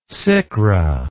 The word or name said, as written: sikra